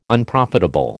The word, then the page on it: Unprofitable: American English